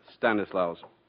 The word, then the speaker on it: Stanislaus: And that butler that Marjorie has had with him, that's Stanislaus, with the accent upon the last syllable.